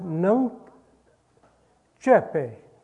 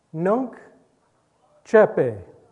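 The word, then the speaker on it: nunc coepi: It is a Latin phrase, and it is pronounced "Nunc Cepe." Nunc Cepe.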